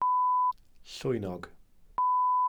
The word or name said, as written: llwynog